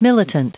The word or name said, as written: militant